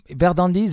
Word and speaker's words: Verdandi: It's uh, yes. Verdandi. The, the, the, pronunciation is correct.